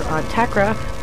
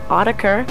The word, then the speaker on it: Autechre: After that, I played some older Autechra, which I've been told can also be pronounced Autechre, and now into some new material out on Hands Productions.